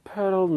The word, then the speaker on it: Poeltl: Poeltl, not so much. I just don't know if he can play. – By the way, Frank Madden is very, very proud of the way you pronounced his last name there.